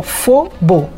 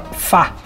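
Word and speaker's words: phở: Uma coisa notável é o Fobo. Na verdade, só se escreve P-H-O, mas não se pronuncia Fo, não, se fala Fá.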